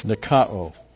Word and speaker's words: nikao: Now, overcame here is nikao. N-I-K-A, long O, nikao.